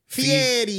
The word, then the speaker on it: Fieri: How do you say it? Fiery or fiery? – Fiery. – Fiery? – Guy Fieri. Fieri.